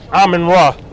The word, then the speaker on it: Amen-Ra: A-S-A-R. – Last name, sir? – Amen-Ra. A-M-E-N-R-A.